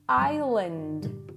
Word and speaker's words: island: Vou pronunciar de novo uma delas e soletrar, e daí tu me diz o que que aconteceu. Island, ISLAND, ISLAND. Island. Então, é um pequeno detalhe. O que que todas elas têm em comum? Todas possuem uma letra que não é pronunciada.